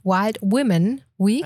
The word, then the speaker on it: Wild Women Week: Ähm, du hast äh, am Sonntag, also gestern, ähm, deine Wild Woman Week, nee, Wild Women Week. – Wild Women.